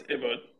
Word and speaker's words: Ebot: So the name is pronounced as Ebot. So the E is like an A, right? So it's like Okay. – Ebot, yeah.